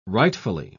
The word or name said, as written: rightfully